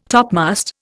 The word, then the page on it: topmast: Indian